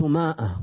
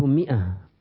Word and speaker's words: mi'a: There's no such thing in Arabic called Ma'a. There's no Ma'a in Arabic. Khamsu Ma'a. What's Khamsu Ma'a? It's called Khamsu Mi'a. Alif is silent.